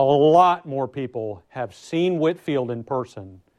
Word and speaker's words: Whitefield: Um, the most famous preacher at the time, for sure, is George Whitefield. Um, and I know the way it's spelled, it looks like it would be Whitefield, but it, I'm on good authority, I'm told it was pronounced Whitfield.